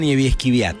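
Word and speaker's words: Niebieskikwiat: Mañana van a estar cenando en la mesaza Luis Novarezio, Carlos Melconian, Barbie Simmons y la periodista, a ver si lo digo bien, Niewieski Wiat, ¿no? – Natalia Niewieski Wiat. – Muy bien, Niewieski. Compañera mía de Clarín, así que lo sé pronunciar a la perfección.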